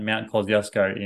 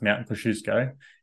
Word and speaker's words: Kosciuszko: And another thing would simply be like that I learned from a Polish Uber driver, that Mount Kosciuszko in Australia is actually pronounced Mount Kosciuszko, and it's named after a Polish individual, which I thought was very interesting.